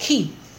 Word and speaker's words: chi: And chiasm comes from the Greek letter chi, which looks like an X, but it's not pronounced X, it's pronounced chi.